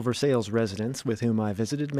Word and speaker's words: Versailles: It looks like the name of a French city pronounced Versailles. Dutch transplant to Versailles, Visha Gurr, set me straight, however, on what to call her town. – No, it's Versailles. – Yeah. – Versailles is in France, you know.